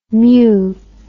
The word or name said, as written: meu